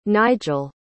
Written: Nigel

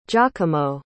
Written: giacomo

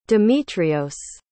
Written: demetrios

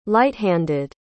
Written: light-handed

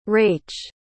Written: rach

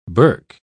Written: burk

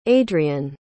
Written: adrian

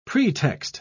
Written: pretext

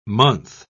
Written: month

